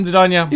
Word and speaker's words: Dedanya: Fooling myself in the process because they even got the two syllables wrong. So I said, I'm Dedanya.